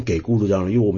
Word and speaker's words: 给孤独长老: 这一点我有点不太能够理解啊，那可能是因为我自己学佛的方向啊，因为我平常学佛的环境主要是粤语和英语啊，那我们知道须达多给孤给，我是念给孤独长老，因为我们在广东话粤语都是 cup 孤独长老，而广东话的这个 cup 并没有像普通话分成给跟给两个读音出来。